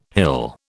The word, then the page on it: hill: British English